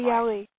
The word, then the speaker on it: Ariely: Now, a fabulous example, one of my favorite examples, is from best-selling author and TED speaker Dan Ariely. I think I pronounced his last name right. – Ariely. – Yes, thank you.